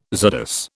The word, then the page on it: czardas: British English